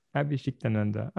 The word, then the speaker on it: Abhishiktananda: Abhishiktananda. Ah bah alors, facile. – Au bout d'un moment. Oui, ça se prononce comme ça.